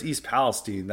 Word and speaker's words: East Palestine: It even happened even with the East Palestine, or it's East Palestine, that's how they pronounce it.